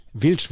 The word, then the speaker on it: Wild: Wilsch wird es tatsächlich ausgesprochen.